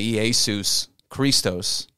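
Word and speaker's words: Iesous Christos: In Greek, they would say Iesus Christos.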